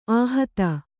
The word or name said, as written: ಆಹಿತ